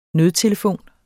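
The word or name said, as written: Nødtelefon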